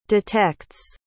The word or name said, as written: detects